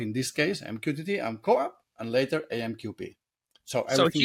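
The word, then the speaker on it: CoAP: You know, how do you pronounce co-op in English? It's this way, co-op? – Yep.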